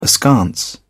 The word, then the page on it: askance: British English